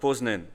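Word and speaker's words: Poznań: העיירות פה, השמות הפולניים מאוד קשים לי להגייה, אבל פה יותר קל וגם אני חושב יותר חשוב להגיד שהוא היה במחוז פוזנה או פוזנן, אותו מחוז בשתי הגיות,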